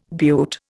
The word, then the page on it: beaut: Indian